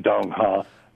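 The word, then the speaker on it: Đông Hà: That's correct, Dong Ha.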